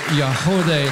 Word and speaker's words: Yagody: The group is called Yahodey. It's spelled Y-A-G-O-D-Y.